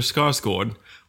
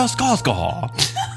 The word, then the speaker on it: Skarsgård: I'm going to pronounce it the Swedish way. – Go ahead. – Alexander Skarsgård.